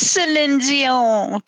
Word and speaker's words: Celine Dion: It is Celine Dion. That's her name in French. That's how you say her name.